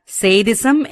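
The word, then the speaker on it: sadism: എന്നാൽ സേഡിസം എന്നത് വളരെ ആഴത്തിൽ വേറുറപ്പിച്ചിട്ടുള്ള ഒരു പേഴ്സണാലിറ്റി ട്രെയ്റ്റ്, അതായത് സ്വഭാവ സവിശേഷതയാണ്. മറ്റുള്ളവരിൽ ആഴത്തിൽ മുറിവേൽപ്പിച്ച് അവരുടെ ദൈന്യാവസ്ഥ കണ്ട് ആനന്ദിക്കുന്ന ക്രൂരതയാണ് സേഡിസം. സേഡിസം എന്നത് UK ഉച്ചാരണമാണ്, US രീതിയിൽ സാഡിസം.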